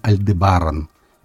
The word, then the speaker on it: Альдебаран: Некоторые поэты писали «пылает красный Альдебаран». Именно так, с ударением на предпоследний слог. Никто не знает точно, как надо правильно произносить название Альдебаран, хотя арабы делали ударение именно на предпоследнем слоге.